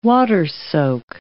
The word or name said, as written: water-soak